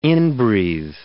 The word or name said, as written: inbreathe